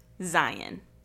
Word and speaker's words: Zion: So, for example, if you say Zion National Park, you're not from Utah. It's Zion National Park. I know it's spelled with the O-N and Zion would make a little bit more sense, but no, it is in fact Zion.